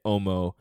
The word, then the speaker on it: homo: Because you would pronounce it without the H anyway. You'd say homo.